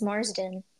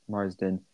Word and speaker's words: Marsden: And Andrew Marsden. I think that's how you say his name. I think it's Marsden. – Marsden. – Yeah, the S comes before the D.